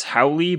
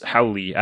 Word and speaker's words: Howle: It's Howley, but it's spelled H-O-W-L-E, so it's like a long E, but nobody ever knows that. I thought it was just How, or How, or How. How? How? – Yeah, that's what most people think, but it's Howley. I don't know why. That's just the way my family pronounces it.